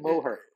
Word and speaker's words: Moher: Moher. Moher. You say it with a really Irish accent. Moher.